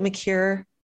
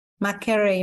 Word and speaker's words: Makerere: How do you pronounce it? Makere? Makerere University. Makerere University.